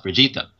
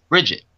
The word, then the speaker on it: Brigitte: And after Brigitte was revealed, Blizzard announced that her name was, again, as I said, pronounced Brigitte. Like Vegeta, but with a B. After a few discussions within the studio, everything else, they did come out and make an announcement saying that in fact her name was indeed pronounced Bridget instead.